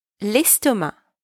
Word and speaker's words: l'estomac: Careful here, you do not pronounce the C. L'estomac.